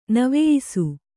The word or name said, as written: ನವೆಯಿಸು